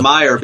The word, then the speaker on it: Meijer: And as you mentioned, Peter Mayer was one of the ones who ultimately, Peter Mayer, however his name is pronounced, I was calling him Major up to this point. You know, like it's Mayer because he's actually named after the supermarket chain.